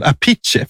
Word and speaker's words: Appice: Och den omusicerande brorsan, han har ju valt att behålla sitt ursprungliga uttal, Apice, för de är ju italienare, va? – Aha.